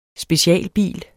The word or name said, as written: specialbil